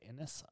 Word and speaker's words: Ines: And their names are James, Innes, I think is how you say it. Innes, I-N-E-S, and Betty.